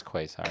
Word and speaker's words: Quasar: Are you talking about Quasar? – Quasar? – It's Quasar. – Kazar? Queso? – Quasar.